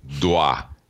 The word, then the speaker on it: tamandua: Is that how you even pronounce that? Tamandua? Tamanda? – Dua.